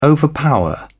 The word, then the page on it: overpower: British English